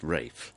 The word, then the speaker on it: Ralph: R-A-L-P-H should be pronounced Rafe, apparently. – Well, it can be, like Rafe Fiennes, but it doesn't have to be.